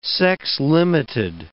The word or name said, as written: sex-limited